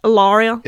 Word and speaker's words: Hilaria: I like to say it like there's no H. Hilaria.